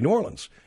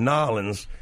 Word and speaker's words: New Orleans: New Orleans, that's how you say it. It's the that's the proper pronunciation. If you're from the north or not from the city and you want to pronounce it so that you pronounce it properly, say New Orleans. If you're local, obviously you have a accent. And, you know, if you're not local and you're going Nolens, everyone's going to know you're a jackass.